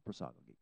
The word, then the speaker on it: prosagoge: That's how it's pronounced, prosagoge.